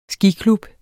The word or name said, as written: skiklub